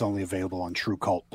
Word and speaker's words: TRVEKVLT: And just to clarify for anybody listening who wasn't watching earlier, it's spelled TRVEKVLT, but pronounced True Cult, right?